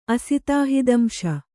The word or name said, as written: ಅಸಿತಾಹಿದಂಶ